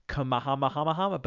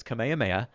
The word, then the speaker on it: Kamehameha: Okay, I actually looked up how to pronounce it because I was like, Kamahamahamahama, but it's Kamehameha.